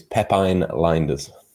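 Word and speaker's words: Pepijn Lijnders: I spoke to somebody before, who used to work with him at Porto, and he assures me it's Pepijn Linders.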